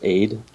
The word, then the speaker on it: Eid: Well, it's actually spelled EID, but it's actually pronounced aid.